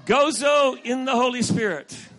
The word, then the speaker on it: Gozo: Gozo. – Did I say that right? – Yes.